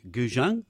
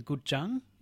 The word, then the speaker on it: guzheng: This is an ancient Chinese instrument, the guzheng. – Yeah, some people call it that, some people call it the guzheng. It's a funny thing, lots of different pronunciations, but it's the same word.